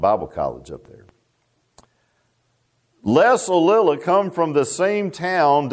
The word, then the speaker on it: Ollila: I know Brother Gibbs was pronouncing his last name wrong. He was calling him Olaf. It's Alila, O-L-L-I-L-A.